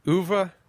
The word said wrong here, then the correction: oeuvre: Oeuvre. – Oeuvre.